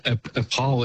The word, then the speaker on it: Opole: So I'm in a little I'm in a place called Opole. It's spelled O-P-O-L-E.